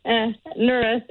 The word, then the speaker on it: Nuris: Am I saying your name right? – Nura. – Mura? – Nuris, N-U-R-I-S.